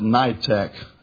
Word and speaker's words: Nidetch: The name is Jean, I call it Nitech, N-I-D-E-T-C-H.